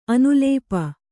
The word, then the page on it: ಅನುಲೇಪ: anulēpa